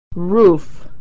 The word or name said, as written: roof